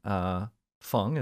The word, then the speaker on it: Feng: By the way, for anyone listening, Fung is actually the correct pronunciation. Just want to throw it out there. It's like Feng Shui, for example. It's pronounced the same way.